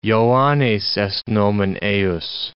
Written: Joannes est nomen eius

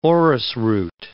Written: orris root

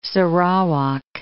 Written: Sarawak

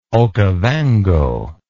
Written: okovango

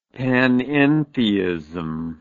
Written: panentheism

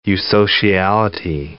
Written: eusociality

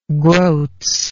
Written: groats

